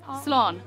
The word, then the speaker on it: Slán: Slán.